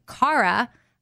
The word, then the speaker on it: Chara: I don't know if you know this or not, but Kara, like a C-H, like a hard C-H, as in Bach, C-H-A-R-A, Kara, is ancient Greek for joy.